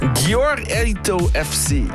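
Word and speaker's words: Győri ETO FC: Gyor Eto FC.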